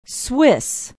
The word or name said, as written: swiss